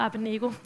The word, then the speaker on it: Abednego: Meshach og Abednego. Det er helt rigtigt udtalt, det kan jeg lige så godt bare sige med det samme.